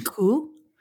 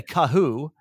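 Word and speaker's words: Khoo: Cool. Yeah, it's it's like it's like woo with a K, but most people say Kahoo.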